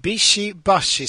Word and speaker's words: Bishi Bashi: Alltså jag slintar alltid med tungan när jag ska uttala, men Bishi Bashi Special heter det alltså.